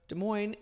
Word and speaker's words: Des Moines: And Des Moines, both words, two words, have an S at the end. – Yeah. – But they are not pronounced. Do not so, pronounce those S.